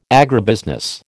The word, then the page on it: agribusiness: American English